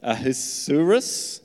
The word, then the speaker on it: Ahasuerus: like King Saul in 1 Samuel or King I can never pronounce this guy, Ahasuerus. Uh, Is that how you say it in Esther? Thank you. I got a nod from the uh, senior pastor.